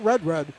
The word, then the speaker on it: Rudrud: Quinn Rudrud, R-U-D, R-U-D, is how his last name is spelled, pronounced not Rudrud, but Rudrud.